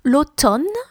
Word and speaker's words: l'automne: Spring, l'été, summer, l'automne, we don't pronounce the M, there's a silent M in autumn, you can check the spelling in the show notes, that's autumn or fall, et l'hiver, it's winter.